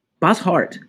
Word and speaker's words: Bosshart: My name is Michael Bryan, and today I have with me a young lady named Caitlin Bosshart. Am I saying that right? – Bosshart. – Bosshart. – Yep. – Just like it's written.